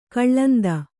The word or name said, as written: ಕಾಲಿಂದಿ